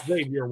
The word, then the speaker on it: Xavier: And now, is it Xavier Worthy or Xavier Worthy? Some people pronounce the X, some people don't. What do you think it is? – I go with the Z. I go with the Z there.